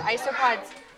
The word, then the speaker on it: isopods: So ice is the kind of stuff you find in the freezer, but isopods, even though it has that sound at the beginning, it's not ice like you find in a freezer.